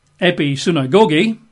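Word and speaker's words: episunagogi (ἐπισυναγωγή): The Greek word there is episunagogi.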